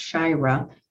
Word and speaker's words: Shari: It is spelled S-H-A-R-I, pronounced Shira, and I owe that to my lovely mother.